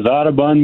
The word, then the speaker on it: Audubon: Is it Audubon, Minnesota? – It is, Audubon, Minnesota.